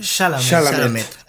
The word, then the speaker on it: Chalamet: Timothy Chalamet o algo así. – Chalamet.